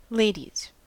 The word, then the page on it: ladies: American English